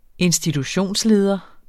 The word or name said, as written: institutionsleder